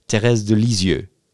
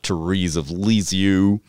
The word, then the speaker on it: Thérèse de Lisieux: Or, if you want to pronounce it correctly in French, Sainte Thérèse de l'Enfant Jésus, Thérèse de Lisieux. That's how you pronounce it, not Therese of Lisieux.